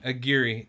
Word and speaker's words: Aguri: It's A-G-U-R-I. – So, Aguri. Aguri. That's what I have wrote down. How's a U a E? – It's just how it's pronounced, dude. – Is it? – It's like Kata.